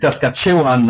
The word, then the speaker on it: Saskatchewan: And I think Saskatchewan, last time I checked, was trying as well.